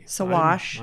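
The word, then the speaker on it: Saguache: Sawash. – I'm learning something today. Sawash. That's S-A-G-U-A-C-H-E.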